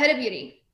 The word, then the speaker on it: Huda Beauty: Huda Beauty, is that how it's pronounced? – Yeah, Huda Beauty.